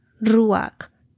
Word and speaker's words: ruach: Viento en hebreo se pronuncia ruach, traducido como aliento, viento, espíritu.